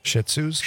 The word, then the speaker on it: Shih Tzus: What do you pronounce those? Shih Tzus? – Shih Tzus. – Shih Tzus. Yeah. Shih Tzus.